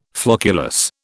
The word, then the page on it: flocculus: British English